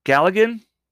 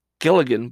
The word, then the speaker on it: Galligan: in which series star Zach Galligan, G-A-L-L-I-G-A-N, Galligan? I think that's how it's spelled. I always thought it was Gilligan, but no, I'm looking at it now. It says Galligan.